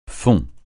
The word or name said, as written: fond